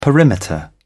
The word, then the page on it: perimeter: British English